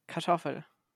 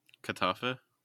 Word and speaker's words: Kartoffel: Kartoffel. – Kartoffel? – It's spelled, if you pronounce it the way it's written, it would be Kartoffel. But I don't think you really want to sound like that.